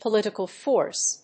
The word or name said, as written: political force